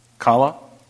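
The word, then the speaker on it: Challah: I think I prefer the Chabalah bread. Am I pronouncing it right, Pastor David? Chabalah? Is that Chabalah bread? Is that, what? Kala? Kala bread? Okay. But then he takes the cup, and he said, "This is the blood of the New Testament, of my blood."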